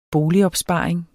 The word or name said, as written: boligopsparing